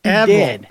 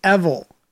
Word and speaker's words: Evel: She actually sent the pronunciation. It's Evel. It's Evel. – Evel. – Evel. And but it's spelled E-V-E-L.